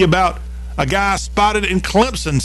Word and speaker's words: Clemson: Got to say it right. It's Clemson. And he was there, I'll tell you why, in our entertainment headlines of this final hour.